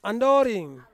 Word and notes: andoring: Ansus

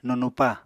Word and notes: nunupa: Apatani

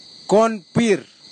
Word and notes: konpir: kwamera; Tanna

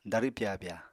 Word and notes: darw pyabya: apatani

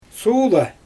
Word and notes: suula: Xyzyl

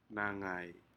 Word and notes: nagai: Aneityum

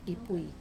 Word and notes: pui: wamesa

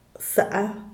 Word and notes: saa: diaz_ordaz

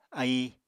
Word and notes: aíi: apatani

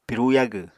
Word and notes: piru yagw: Apatani

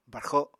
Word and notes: barxo: Apatani

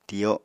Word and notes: tiyo: Apatani

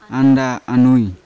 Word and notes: anda anui: Yawa

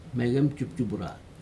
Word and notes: inmeg injupjupura: Aneityum

